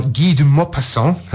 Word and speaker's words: Maupassant: Based on the 19th century novel by Guy de Maupassant, hope I'm pronouncing that right. – Beautifully pronounced.